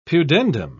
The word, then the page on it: pudendum: British English